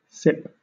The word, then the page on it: sip: British English